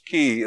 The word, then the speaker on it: Cay: Key is spelled C-A-Y.